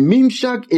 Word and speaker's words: Mimshach: Mimshak is a Hebrew word that is spelled M-I-M-S-H-A-C-H. Mimshak.